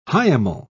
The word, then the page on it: hiemal: British English